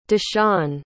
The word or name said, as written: deshun